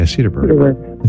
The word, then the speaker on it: Cedarburg: Cedarsburg, Wisconsin. Cedarburg. Did I say that right? Yeah, Cedarburg.